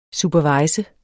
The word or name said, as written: superviser